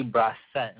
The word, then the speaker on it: Brosset: So what we've done this week is I invited, last minute, of course, so thanks for being ready very quickly, Becky Brosset. Am I saying that right, Becky? – Yeah, that's fine.